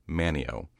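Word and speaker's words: Manteo: There is a coastal town in North Carolina of the same name, and the people around there have instructed me that it is pronounced Manio, despite there being a T in the word.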